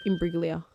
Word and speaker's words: Imbruglia: Imbruglia. – Soft G. – Imbruglia. – Bang. – Imbruglia. Natalie Imbruglia.